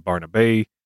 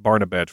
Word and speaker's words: Barnabet: I'll take this opportunity to point out the pronunciation of Clementine's name varies depending on source, from Barnabet to Barnabay to Barnabay to Barnabet. I'll be going with Barnabet for the most part, but you might hear it differently from time to time.